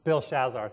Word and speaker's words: Belshazzar: Belshazzar.